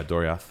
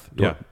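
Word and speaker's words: Doriath: And their kingdom was known as, I forget how to pronounce it, Dorioth? – Yeah, Dorioth. Dorioth.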